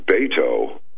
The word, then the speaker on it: Beto: It's pronounced Beto.